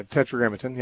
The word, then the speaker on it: tetragrammaton: It's my understanding that the tetragrammaton, if that's the proper pronunciation. – Yeah, tetragrammaton, yeah. Mm-hmm.